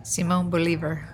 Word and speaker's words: Simón Bolívar: Simón Bolívar.